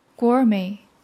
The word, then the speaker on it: gourmet: Gourmet, gourmet. – 미식가. 프랑스 말에서 왔기 때문에 T는 묵음입니다.